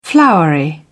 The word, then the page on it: flowery: British English